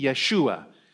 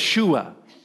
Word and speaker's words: Yeshua: Would you say it with me? Yeshua. Yes. Yeshua.